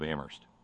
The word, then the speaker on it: Amherst: Signed, Terry, in the People's Republic of Amherst, where, I will add, only the H is silent.